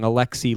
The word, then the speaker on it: Alexi: And how do you say the Alexi? – Alexi Lafreniere. – Yes.